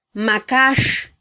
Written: makax

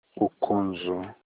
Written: Ukonzu

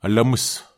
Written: alemus

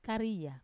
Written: karia

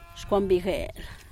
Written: xcuan bigel

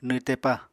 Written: nwtepa

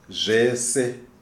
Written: Zvese

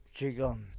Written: cheyon

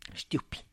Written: xtyupy

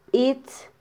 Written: bitz

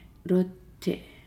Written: lote

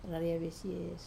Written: rarai vesie

